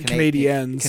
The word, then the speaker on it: Canadiens: Not a fan of the Canadians. I guess it's pronounced the Canadiens.